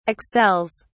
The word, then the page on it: excels: British English